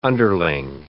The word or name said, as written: underling